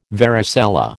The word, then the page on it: varicella: American English